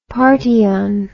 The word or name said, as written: party on